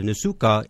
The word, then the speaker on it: Nsukka: Nsukka in Nigeria. Esther, welcome to Catholic Schools Today. – Thank you very much, and you pronounce that word very well.